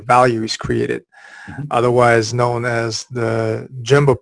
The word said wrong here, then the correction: Gemba: process innovation, if I'm pronouncing that right. Can you tell us a little bit more about this concept, and how can other organizations actually can benefit from that? – Sure. So it's Gemba, actually.